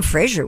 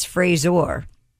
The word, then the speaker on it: Fraser: Brandon Fraser was talking about that over the weekend, that people are pronouncing his name wrong all these years. – What's his name? – It's not Fraser, it's Frazor.